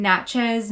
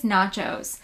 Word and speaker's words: Natchez: I hope I'm saying that right. I was asking Carson last night how to say it, and he's like, I think it's nachos. Definitely not nachos. – Do that. – It's not nachos.